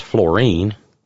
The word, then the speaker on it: Florian: Now that's F L O R I A N. It is pronounced fluorine, you know, like fluorine gas, uh, the, you know, the element. Uh, but it's St. Florian.